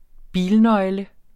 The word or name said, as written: bilnøgle